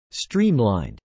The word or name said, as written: streamlined